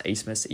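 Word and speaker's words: Abmas: So for the high-priced guards, Max Asmus, yes, it's pronounced Asmus, even though it's spelled Abmus. You can blame the German language for that.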